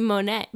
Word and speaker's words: Monet: In Spain, his name is Monet. Oh. I'm not sure if that's how you pronounce Monet in Spanish. Is that correct? – How do you spell it? – M-O-N-E-T, just like the painter.